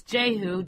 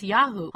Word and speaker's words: Jehu: Okay, now, of course, in our modern English Bible, it's Jehu, J-E-H-U. But that's not really the way it's pronounced or spelled. In ancient Hebrew, it's Yahu.